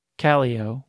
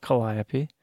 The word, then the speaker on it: Calliope: We mispronounce nearly all of them, starting with Calio, which is not Calliope.